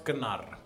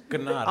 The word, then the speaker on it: Gnarr: It's pronounced Gnarr. – Is it really? – Yes, Gnarr.